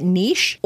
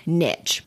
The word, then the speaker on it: niche: Completely up to you, both are appropriate and correct. So you can pronounce it niche or niche.